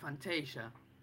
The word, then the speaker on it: Fantasia: Em, y, bueno, Fantasía es una peli, bueno, en inglés le pronuncian Fantasia.